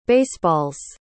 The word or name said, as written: baseballs